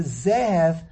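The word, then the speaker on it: Zehev (זהב): When you change it from zohov to zehev, you also automatically change the mila el mila ra.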